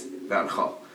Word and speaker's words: Van Gogh: And American English does not pronounce Van Gogh's name correctly. It's Van Gogh.